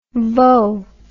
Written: voe

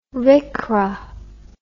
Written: rcra

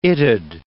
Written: it'd

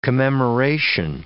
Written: commemoration

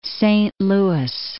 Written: Saint Louis Park

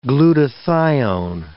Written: glutathione

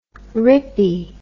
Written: rippy

